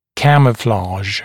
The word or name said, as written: camouflage